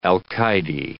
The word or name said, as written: alcayde